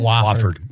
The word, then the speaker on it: Wofford: Wofford. Is that how you say it? – Wofford. – Wofford.